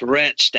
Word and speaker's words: Wretched: It's Wretched, actually. – Oh, Wretched, I should have mentioned that. That's my fault. It looks like Wretched without the W, but yeah, it's pronounced Wretched.